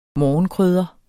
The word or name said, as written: morgenkrydder